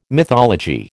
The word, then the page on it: mythology: American English